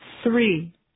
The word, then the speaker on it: three: We have three cars. Three. Three cars. We have three cars. – تلفظ سه یه خود دقت و تمرین میخواد. باید نقطه‌بونی بگید و سریع بچسبونید به ر. بگید سه.